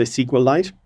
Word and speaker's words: SQLite: I often try and call it Requalite because I think most people say SQLite, but it's kind of awkward.